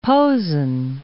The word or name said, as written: Posen